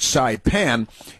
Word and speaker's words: Saipan: Now, the population of Saipan, which is pronounced Saipan, is 43,385, according to the U.S.